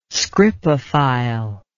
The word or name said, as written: scripophile